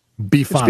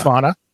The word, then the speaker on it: bifana: For anyone who has never had a Bifana and is pronounced Bifana. – It's Bifana. Yeah.